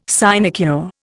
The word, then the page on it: sinecure: Indian